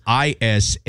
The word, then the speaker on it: Isaias: We follow four syllables. Jeez. – It's so much. – I feel like there's more syllables than letters in, yeah. in the name. – So it's Isaias,